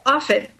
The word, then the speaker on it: Offit: In fact, Children's Hospital of Philadelphia, CHOP, CHOP as it is called, where Dr. Paul Offit, which rhymes with profit,